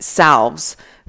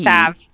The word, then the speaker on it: salve: Salves for JT. – Salve. – Salve. Don't say salve. – It has an L in it, but it's pronounced salve.